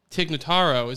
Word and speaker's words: Tig Notaro: So Tig Notaro, is that how you pronounce her name? – Yeah.